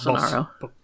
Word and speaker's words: Bolsonaro: It's Bolsonaro.